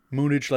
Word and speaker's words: Moonage: Moonage like spillage and cabbage? Yeah, that's how you've been pronouncing it all year, and I've just given in.